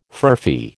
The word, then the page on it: furphy: American English